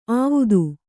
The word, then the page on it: ಆವುದು: āvudu